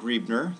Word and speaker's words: Griebner: And my name is Don Griebner. That's spelled G-R-I-E-B-N-E-R.